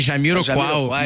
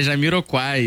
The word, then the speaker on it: Jamiroquai: Não, é porque eu falei Jamiroquai, mas é Jamiroquai, eu li errado. Troquei o L pelo U.